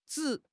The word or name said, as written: zhi3